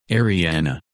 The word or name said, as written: Arianna